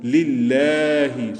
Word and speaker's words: lillahi: So, at-tahiyyatu lillahi. So, lam is thin, and we also stretch it for 2 seconds. Lillahi, and the ha is heavy.